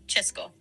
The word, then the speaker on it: Česko: Si quieres referirte al país de forma corta, como suelen hacerlo hoy en día, dicen Česko, que se pronuncia Česko. Česká República, Česká República. Česko, pronto.